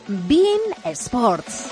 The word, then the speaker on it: beIN Sports: Sí, se escribe beIN Sports, pero se pronuncia beIN Sports.